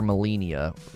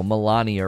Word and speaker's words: Malenia: I mean, I looked up how to say her name. That's what everybody voted on Reddit, that you're supposed to call her Malenia. I think a lot of people would call her, you know, Melania or whatever, but I think Melania makes the most sense when you look at her name.